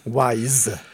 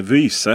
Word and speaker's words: Wyze: För användare av kameror från Wise, som jag antar att det uttalas som. Det stavas alltså W-Y-Z-E. Wise. Just det, eller vyse mm. kanske man ska säga på svenska då.